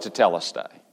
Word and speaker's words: tetelestai: Now the word Jesus is using here, and you've heard the word I'm sure many times before, it's the word tetelestai. How do you pronounce that word? We'll go with tetelestai.